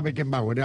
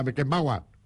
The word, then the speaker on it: Beckenbauer: También nos enseñaron que no era Beckenbauer, era Beckenbauer.